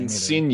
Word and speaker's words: Insigne: How do you say? I don't know how to say his name. Insigne. – Either. You sure about that? Yep. Okay. It's it's the bit silent G.